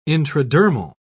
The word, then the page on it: intradermal: British English